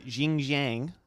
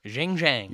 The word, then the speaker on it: Xinjiang: According to the Xinjiang Evening Post. – Xinjiang.